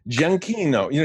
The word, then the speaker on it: Giacchino: Yeah, Giacchino.